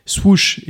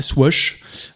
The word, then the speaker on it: swoosh: alors je, crois que ça se prononce aussi en anglais, swoosh et pas swash.